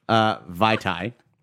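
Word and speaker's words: Vaitai: Vitae. Which I think that rhymes with Mai Tai.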